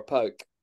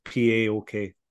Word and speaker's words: PAOK: Or Pauk. – Yeah. I always thought it was PAOK. Like, that's how you would pronounce it. It's not. The Karapetis is the manager of.